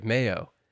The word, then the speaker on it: Majo: So Marie says M-A-J-O, Majo. – There we go. – Versus a soft J, Mayo.